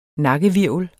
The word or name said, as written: NAKKEHVIRVEL